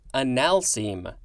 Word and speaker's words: analcime: First word for this episode is, I think it's pronounced, analcime.